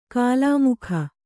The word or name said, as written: ಕೋಲುಮುಕ್ಕ